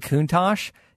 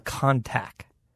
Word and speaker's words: Countach: And he's Canadian, and if I recall on his show, the one thing that always was weird to me is the Lamborghini Countach, he would call a Contac. – Mm. – Like, if you think of how it's spelled.